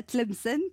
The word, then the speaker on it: Tlemcen: Vous êtes né de l'autre côté de la Méditerranée, à Tlemcen, pendant la guerre d'Algérie. – Vous l'avez bien prononcé.